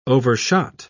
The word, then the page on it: overshot: British English